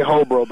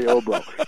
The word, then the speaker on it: Hobro: The H is silent. You could say Hobro, but it's really Obro.